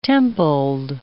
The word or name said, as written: templed